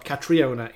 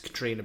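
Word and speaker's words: Caitríona: It's not Catriona, it's Katrina Balfe.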